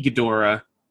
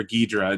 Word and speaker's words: Ghidorah: The ability to see Ghidorah or Ghidra.